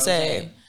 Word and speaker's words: Rosé: It's not Rosie. That's why I want to say like the pronunciation. – Yeah, it's right. – Rosé.